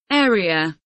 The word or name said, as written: area